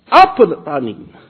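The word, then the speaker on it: apothanin (ἀποθανεῖν): Now, that Greek word, the apothanin. Syllable is on the first. Apothanin.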